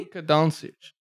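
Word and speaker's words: Dončić: You're still mispronouncing his name. – Who? – Doncic. – Doncic. – I'm pretty sure it's Doncic, yeah. – Really? – Luka Doncic. – That's the correct way to say There you go.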